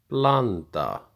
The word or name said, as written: Blanda